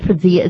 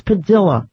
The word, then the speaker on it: Padilla: It is not Padilla. It's Padilla. You know, we all think we're so smart. We're saying it the Spanish way. His name is actually, it is pronounced with a double L.